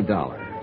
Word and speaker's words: Dollar: It's Dollar, Johnny Dollar. – Dollar, huh? – Yeah, rhymes with collar.